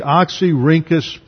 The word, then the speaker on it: Oxyrhynchus: The Oxyrhynchus papyri. Now, for those on tape, that's spelled O-X-Y-R-H-Y-N-C-H-U-S. O-X-Y-R-H-Y-N-C-H-U-S.